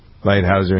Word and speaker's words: Lighthizer: Both the delegates from the United States, Robert Lighthizer, is how you pronounce his name? – Lighthizer, yeah.